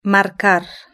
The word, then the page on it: marcar: European Spanish